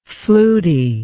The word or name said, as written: fluty